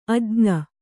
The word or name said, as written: ಅಜ್ಞೆ